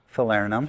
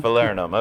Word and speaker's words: Falernum: Falernum. How do you pronounce it? – Falernum. – Falernum, okay.